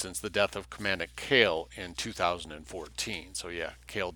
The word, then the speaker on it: Koehl: When NSWPP Commander Matt Cale, K-O-E-H-L, and it's not Cole, it's pronounced Cale, reorganized the party as the New Order in 1983, Mr. Kerr was one of its founding associates.